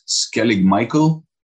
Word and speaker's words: Skellig Michael: You're British, you might know Skellig Michael in Southern Ireland. Is that how you say it? I think so.